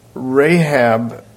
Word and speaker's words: Rahab: So, Rahab is pronounced the same way as the lady whose name was Rahab in Jericho, but it's a slightly different spelling in Hebrew.